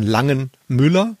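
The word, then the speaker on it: Langen Müller: Der wird, glaube ich, ausgesprochen Langenmüller, weil es ist nicht ein Name, sondern es sind zwei Verlagsgründer gewesen.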